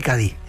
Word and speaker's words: Cádiz: Escritor de Cádiz y Cádiz.